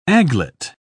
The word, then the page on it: aiglet: British English